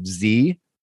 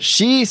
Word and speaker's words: Xi: But she says X-I-Z is pronounced she.